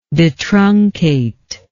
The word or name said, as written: detruncation